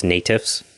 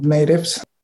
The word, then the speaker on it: NATIFS: Can you tell us a little bit about the organization that you're involved with, the North American Traditional Indigenous Food Systems, or I believe it's pronounced NATIFS, if you're using the acronym? – Yes, we use the acronym NATIFS.